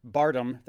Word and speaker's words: Bardem: And I will say that Javier Bardem, that's how you pronounce his last name. – Bardem.